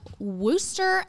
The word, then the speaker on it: Wooster: So on today's episode, again, this is Gabe Griffith, and he is from Wooster, Ohio.